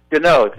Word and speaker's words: Gounod: Gounod. – Gounod, exactly.